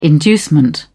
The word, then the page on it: inducement: British English